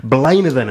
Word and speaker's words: Blaenavon: So among the crop of Britain's bright young bands, these guys, Blainavon is the way I'm going to pronounce it, B-L-A-E-N-A-V-O-N, they began as three school friends in Hampshire at the age of about 15.